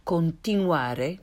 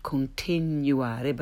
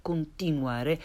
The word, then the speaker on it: continuare: No y or y consonant sound there. Continuare, not continuare, but continuare. And università, rather than università.